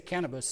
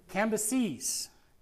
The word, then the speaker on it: Cambyses: Let me say that right. I say Cannabis, sounds like he's, you know. Cambyses. It was actually Cambyses the First.